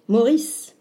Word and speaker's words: Maurice: Maurice Un délice à prononcer, écraser fort le mot et tirer longuement sur le ris.